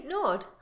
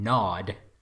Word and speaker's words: nod: G N A W E D, Nord. – Again, there's no R in nod.